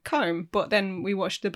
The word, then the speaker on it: Colm: Is it Colm? – Colm. Yeah, probably. – But I used to pronounce it Colm. – Colm. – Colm. – Colm. – You know, like calm. – As in the feeling. Word calm.